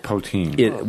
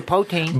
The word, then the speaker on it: poutine: I've also heard it pronounced poutine. – Well, that's incorrect. That's incorrect. – But no, you're planning to say poutine as well, though. – It's poutine.